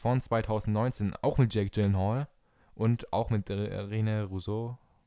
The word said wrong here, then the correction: René Russo: Rousseau. René Russo.